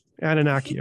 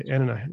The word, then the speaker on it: Anunnaki: Anunnaki. – Okay. Anunnaki.